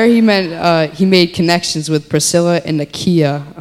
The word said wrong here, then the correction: Aquila: Um, no, I said it wrong again. Um, when we were meeting with Pastor Nate on Thursday, it's Aquila, not Ikea.